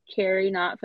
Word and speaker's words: Keri Knott: I am always available on all social media platforms at Carrie Not Photography. And my name's a little funny, so I will spell it for you. That is KerieKnott and the word photography. DM me, direct message me.